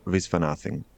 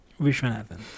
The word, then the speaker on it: Viswanathan: E lo pronuncia Viswanathan. – Viswanathan.